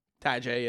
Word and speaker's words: Tyjae: Or Tajay. Am I saying that right? – Tajay, yeah.